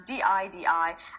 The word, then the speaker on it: DIDI: Even though when it first announced, I thought it was DD or DID, but they confirmed it's pronounced as DIDI.